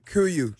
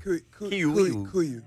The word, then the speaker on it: KUIU: Kuyu. – He doesn't know how to pronounce it. – K-U-I-U.